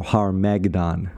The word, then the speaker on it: Harmagedon: Man, God, heaven, and Harmageddon, or Harmagedon. – Harmagedon. – the emphasis on the H there for Har, as in mountain, Hebrew mountain, is Meredith Klein's latest book, and it is phenomenal.